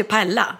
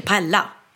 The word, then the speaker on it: paella: uttal, stavas ju paella, men det får du inte säga. Du säger inte paella, du säger paella.